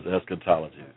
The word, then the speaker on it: eschatology: But it's eschatology, pronounced as eschatology, two Greek words. The first, eschatos, means last or final. The second, logos, means word or study.